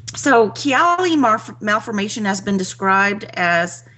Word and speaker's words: Chiari: The affliction, known as, am I pronouncing this right, Chiari? – No, I think it's Chiari.